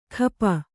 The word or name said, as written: ಖಪ